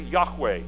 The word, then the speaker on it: Yahweh: In Hebrew, and this could certainly be pronounced a variety of ways, but as I learned it in seminary, Yahweh is his name.